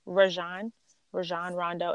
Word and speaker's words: Rajon Rondo: The one where, is it Rajon Rondo? Is that how you say his name? – Yeah. – Rajon Rondo and Chris Paul get into their little brawl.